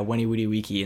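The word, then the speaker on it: veni vidi vici: And I was talking to him about that, but like nobody really knew what it meant, but I would pronounce it to them like, yeah, Wenny Woody Weeky, and they'd be like, what? Did you just cast a spell on me or something? And I'd be like, No, that's how it was pronounced in Latin, but yeah, that's Latin pronunciation.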